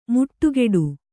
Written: ಮುಟ್ಟುಗೆಡು

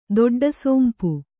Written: ದೊಡ್ಡಸೋಂಪು

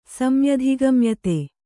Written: ಸಮ್ಯಧಿಗಮ್ಯತೆ

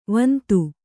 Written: ವಂದಿ

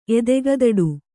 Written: ಎದೆಗದಡು

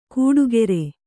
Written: ಕೂಡುಗೆರೆ

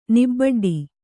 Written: ನಿಬಡ್ಡಿ

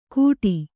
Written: ಘುಟಿ

